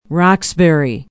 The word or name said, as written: Roxbury